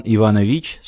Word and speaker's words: Иванович: Инженер человеческих душ Иван Иванович, с ударением на последнем слоге, встретил его у двери.